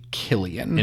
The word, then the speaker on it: Cillian Murphy: And then we talked about what I keep saying Cillian Murphy. It's actually Killian. – It is Murphy. – Killian Murphy. – It's written correctly. Okay. I just made up the you fact that it was a soft C, I guess.